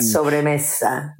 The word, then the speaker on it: Sobremesa: Sobremesa. – This. Yes, that's the one. – Sobremesa.